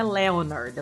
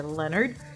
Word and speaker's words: Leonard: Lembrando que se escreve Leonard, mas esse O é mudo, ele não é pronunciado. Então não é Leonard, é Leonard.